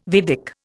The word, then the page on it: Vedic: Indian